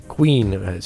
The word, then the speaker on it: Qween: Queen, spelled Q-W-E-E-N. – Yep. – Really? – How do they do that? – I don't know. It's pronounced the same way, isn't it? – Yeah, you still figured it out.